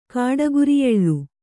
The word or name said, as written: ಕಾಡಗುರಿಯೆಳ್ಳು